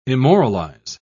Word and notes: immoralize: British English

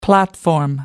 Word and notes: platform: American English

